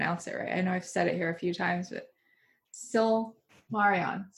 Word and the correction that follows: Silmarillion: Silmarion. – Silmarion? – Silmarion. – I'm going to look up how it's spelled. – People know what we're talking about. If they're a Belgariad fan, they're a Lord of the Rings fan, and they'll know the Silmarillion. Silmarillion. – Okay. – S-I-L-M-A-R-I-L-L-I-O-N.